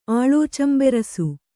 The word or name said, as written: ಆಳೋಚಂಬೆರಸು